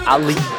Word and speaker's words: Ahli: Al Healy Show. Am I saying this football club team name right? Oh, Ali. It's spelled A-H-L-I.